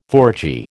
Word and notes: theurgy: American English

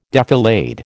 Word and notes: defilade: American English

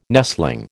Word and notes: nestling: American English

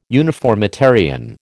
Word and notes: uniformitarian: American English

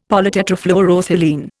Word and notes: polytetrafluoroethylene: Indian

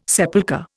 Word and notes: sepulchre: Indian

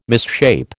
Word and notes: misshape: American English

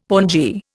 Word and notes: pongee: Indian

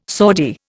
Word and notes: Saudi: Indian

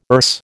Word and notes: Erse: American English

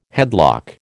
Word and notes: headlock: American English

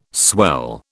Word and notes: swell: British English

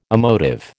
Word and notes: emotive: American English

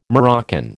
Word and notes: Moroccan: American English

